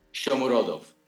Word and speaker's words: Shomurodov: Shomurodov. – Yeah, Shomurodov.